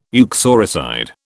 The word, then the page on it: uxoricide: British English